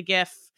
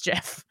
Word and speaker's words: Jif: The thing about the organic-y kind of peanut butter vibe is that there's so many preservatives in the Skippy and the Jif or the Gif. No, it's Jif.